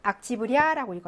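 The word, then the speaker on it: 악지브리아 (октября): 이것도 남성 명사인데 강세가 생격되면서 야로 이동을 해서 악지브리아라고 읽어요.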